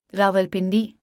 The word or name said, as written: Rawalpindi